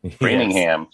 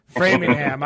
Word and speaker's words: Framingham: Framingham. Yes. – Just like it's spelled. – Framingham.